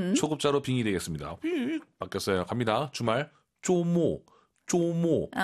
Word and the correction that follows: 周末: 쪼우모어입니다. 왜? 오유는 어예요. 쪼우모어. 어로 끝내야 됩니다. 쪼모어.